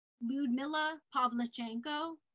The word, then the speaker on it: Lyudmila Pavlichenko: She also did a road trip with Lyudmila Pavlichenko, which that is how you say it.